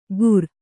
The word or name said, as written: ಕೂರ್